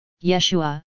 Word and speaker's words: Yeshua: They took the noble figure of Jesus, Yeshua, as his real name should be pronounced, a Hebrew name which means Savior by the way, which is interesting.